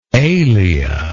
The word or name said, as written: alea